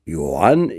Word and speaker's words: Ioan: Ioan e ortografiat așa cum îl pronunță românii și nu Johan ca la german sau Ianoș ca la ungur sau Iovan ca la slavi.